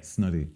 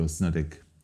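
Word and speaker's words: Ó Snodaigh: Snoddy. Whereas a lot of people go Snoddick. There's three brothers, Ronan, Colm and Rosso Snoddick from Keela. – Yes. – The band Keela, and everyone would be quite aware of them. And everyone says Snoddick. So you said it right. – Here Snoddick. In Ireland, Snoddick. Most people know who they are. Yeah. – You don't pronounce the G-H.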